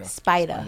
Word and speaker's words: Spida: Is it Spider or Spider? – Spider. – No. – But, okay. How does he spell it? Spider. Okay. – S-P-I-D-A.